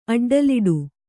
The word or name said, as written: ಅಡ್ಡಲಿಡು